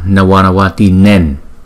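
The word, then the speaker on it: Navanavatīnām: Thì Ngài nói là đọc là Nama Nawanawati Nen, không phải là Nam. Cái chữ này là Tinen chứ không phải là Tina Tinam. Nama Nawanawati Nen không phải là Nam, bởi vì chữ này kết thúc ngay chỗ ta dừng nghỉ hơi.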